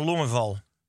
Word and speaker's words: Longeval: Dus we hadden een, eh, of we hebben een vraag van Ede, ene Alexander Longval. Mooi. Hoe zou jij het uitspreken? Alexandre Longeval. – Of Alexander Longeval. – Dat kan natuurlijk ook. – Ehm, wat is jullie mening over tattoos en kunnen deze aanvaardbaar, stijlvol of zelfs snobistisch zijn?